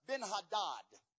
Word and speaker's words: Ben-Hadad: King Ben-Hadad, as it's pronounced.